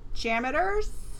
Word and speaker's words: Jaminators: And over at the Canadian Pavilion, Jaminators? I don't know how to say it. – You were right the first time. – Oh. Jaminators will perform at the Mill Stage.